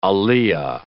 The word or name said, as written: aliyah